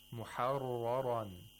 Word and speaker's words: مُحَرَّرًا: Both of them were pronounced with tafkhim. Muharraran. Okay, so you shouldn't pronounce one ra with tarqiq and another one with tafkhim. Both have tafkhim.